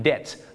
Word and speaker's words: debt: debt ต้องออกเสียงว่า debt I'm in debt ไม่ใช่ debt นะฮะบ้านเราชอบออกเสียง debt ว่า debt ไม่ได้นะครับอย่าลืมครับ